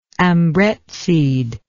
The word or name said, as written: ambrette seed